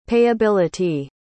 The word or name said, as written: payability